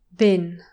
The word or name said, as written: bin